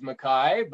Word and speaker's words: McKay: Even it is a Scottish last name, and my understanding is that the correct pronunciation in the clan is McKay.